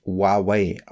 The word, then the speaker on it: Huawei: Der prominenteste Name auf dieser Liste ist Huawei, was eigentlich Huawei ausgesprochen wird.